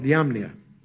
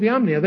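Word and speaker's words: Jamnia: called called Yabnia, or anyway, it's spelled with a J, J-A-M-N-I-A. At the Council of Yabnia, they were upset because the Septuagint had become the Christian's Bible.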